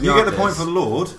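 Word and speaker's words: Lorde: Is it Lord? – Yeah, that's one point. – Lordy, or how do you say it? – I think it's Lord.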